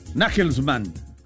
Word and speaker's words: Nagelsmann: Nagelsmann, así se pronuncia, ¿pero? – Sí, o Nagelsmann.